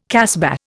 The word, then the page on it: casbah: Indian